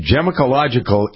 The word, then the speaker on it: gemological: Grant Mobley is a Gemological Institute certified gemmel. I love that. Gemological Institute. I think I added the, though, Grant, I think I added an extra syllable in it. Gemological. It's gemological. Yeah.